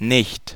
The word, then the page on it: ei: /ˈei/